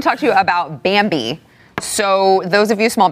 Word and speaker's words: Bambee: So Bambi, it's spelled B-A-M-B-E-E, was created specifically for small businesses like yours.